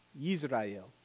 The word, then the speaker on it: Jezreel: In Hebrew, the name Israel would be pronounced Yisrael. And Jezreel would be pronounced Yisrael. They're homophones. They're homophones.